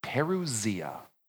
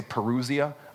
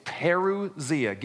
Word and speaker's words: parousia: It's pronounced like this, parousia. Now, don't listen to me say it casually, because I always say parousia. I say it wrong, all right? It's parousia.